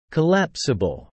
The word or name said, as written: collapsible